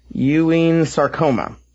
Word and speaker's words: Ewing's sarcoma: Ewing's sarcoma.